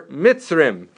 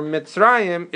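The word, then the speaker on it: Mitzrayim (מצרים): But if not, if you didn't know the Mikra Sofrim, you might pronounce it Shamim or Mitzrim, right? So the fact that we know to pronounce it Shamayim or Mitzrayim is because Mikra Sofrim.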